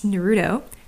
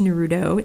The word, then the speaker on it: Naruto: not in least including how to pronounce Naruto, which is not pronounced Naruto, it's pronounced Naruto, or with emphasis on the Na, Naruto.